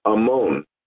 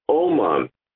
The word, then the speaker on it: amon: The Hebrew word is amon. It was explained that we should not pronounce it Amon, but rather Oman, craftsman.